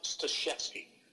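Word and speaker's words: Staszewski: And it's a Polish last name. It's actually, I know it's read that way, but it's pronounced Stashevsky.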